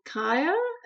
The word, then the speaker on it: Cayre: So I've chosen a book called The Godmother by Annalore Kaya. It's translated from the French, so her surname is spelt C-A-Y-R-E.